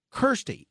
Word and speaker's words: Kirstie: She, when people would call her Kirsty or Christy, it drove her crazy, and she and including big Hollywood stars, she would have to tell them Kirsty. It rhymes with thirsty.